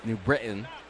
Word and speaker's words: New Britain: The T, when pronounced by a nutmegger, is silent there. New Britain.